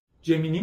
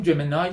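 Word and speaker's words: Gemini: الانم گوگل این جمینی ما بهش میگفتیم جمینای، تلفظ درست‌تریه بگم عادت کنیم بگیم جمینای، بعد نیست تلفظ جهانی رو بگیم.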